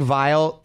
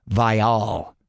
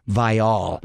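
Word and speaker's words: Viall: By the way, Nick Vile, they are now pronouncing his last name Vial. – What? – Yes, and he made it a point to tell everyone that they've been pronouncing it wrong. It's actually Nick Vial.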